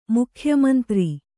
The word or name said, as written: ಮುಖ್ಯಮಂತ್ರಿ